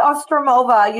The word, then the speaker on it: Ostroumova: It's Kat Ostroumova.